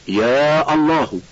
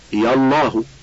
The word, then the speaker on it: يا الله: الجمع بين يا وأل: لا يجوز أن يدخل حرف النداء على الاسم المبدوء بأل إلا فيما يأتي: واحد، نداء اسم الله تعالى، فتقول يا الله بقطع همزة الوصل ومد يا، ويصح أن تقول يا الله بإسقاط همزة الوصل وإسقاط الألف من يا نطقا فقط.